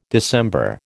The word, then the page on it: December: American English